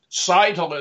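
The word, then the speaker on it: Scytl: Seitel is spelled S-C-Y-T-L, a Spanish company.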